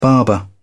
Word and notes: barber: British English

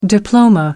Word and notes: diploma: American English

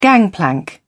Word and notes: gangplank: British English

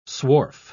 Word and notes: swarf: British English

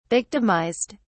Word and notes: victimized: British English

